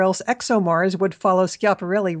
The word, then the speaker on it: Schiaparelli: A lot of people very curious, of course, about Schiaparelli. I'm trying to pronounce that with the proper Italian pronunciation of it.